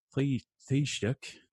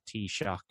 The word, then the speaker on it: Taoiseach: All right, I would say cup of tea, and then shock. Like, oh, I just got shocked by that Taoiseach. Is close enough.